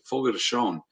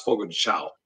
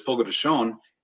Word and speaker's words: Fogo de Chão: Fogo de Chão. I always thought it was Fogo de Chão. – That's what I thought. – A lot of people call it that, but it's Fogo de Chão.